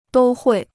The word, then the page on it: 都会: dū huì